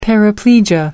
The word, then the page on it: paraplegia: American English